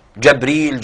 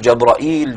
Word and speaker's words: جبريل: جبريل، جبرائيل، جبرائيل، جبريل. هذه الألفاظ جبريل وميكائيل، هذه الألفاظ عجمية وليست عربية، لذلك لما عربت يعني كثر استخدامها عند العرب اتسع العرب في نطقها.